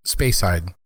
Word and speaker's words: Speyside: And then the Speyside, is that how you pronounce it? – Speyside.